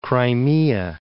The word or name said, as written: Crimea